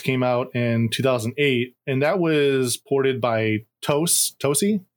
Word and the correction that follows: Tose: Did I pronounce that right? Did anyone see Tose? Yeah, I don't know how you say it. – Tose. I believe it's pronounced Tose.